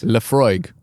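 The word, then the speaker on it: Laphroaig: Am I saying that correctly? – Actually, on the packaging, in the parentheses, it shows the pronunciation. – So which is? – Lefroig. – Lefroig. – It looks like it's Lefroig.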